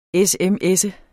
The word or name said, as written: sms